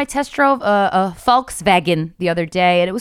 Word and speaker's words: Volkswagen: But one of the things was Volkswagen is actually Volkswagen, because obviously it's not an English American company.